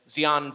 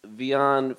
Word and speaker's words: Xi'an: And I'm doing my best to pronounce it, Xi'an Famous Foods. I think it's an approximation of how it's supposed to be said. – It's Xi'an Famous Fods.